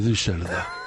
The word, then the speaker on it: Düsseldorf: A ver el vampiro de Düsseldorf. – ¿De dónde? – De Düsseldorf. Alemania, Carlos. Düsseldorf. Es que Düsseldorf lleva la Düsseldorf, que se escribe, lleva dos puntos en la U. Y entonces dice Düsseldorf.